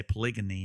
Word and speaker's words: polygyny: And I say polygyny, not polygamy, because I was wondering if you're mispronouncing.